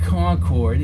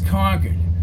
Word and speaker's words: Concord: I've never been up past Concord, said Jones. It's not Concord, it's Concord.